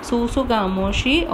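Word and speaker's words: tsutsugamushi: Here T is silent, susugamoshi or Rickettsia orientalis.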